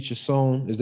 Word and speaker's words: Chiasson: Getting into this fight, pretty evenly matched, I felt, in the first round, but I felt like Macy Chiasson, am I saying this right? – Yep.